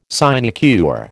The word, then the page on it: sinecure: American English